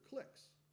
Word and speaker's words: cliques: You know, we can have, you know, that there's a word for that. It rhymes with click. – Yes. – That's what alliances are. They're cliques.